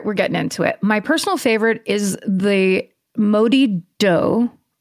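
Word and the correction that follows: Moddey Dhoo: Oh, sorry. Pronounced Morty Doo.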